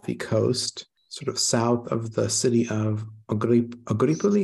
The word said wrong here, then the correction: Agropoli: How do you say it? Agripoli? – Agropoli.